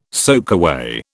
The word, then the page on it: soakaway: British English